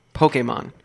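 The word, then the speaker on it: Pokémon: No, Pokemon. Pokemon. There's an accent on the E.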